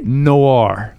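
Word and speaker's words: Noire: Elle est Noir. Voilà. – Noir. – Je précise que si on prononce comme ça, c'est parce qu'en américain, ça se prononce comme ça, n'est-ce pas? – En américain, oui.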